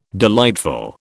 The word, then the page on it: delightful: British English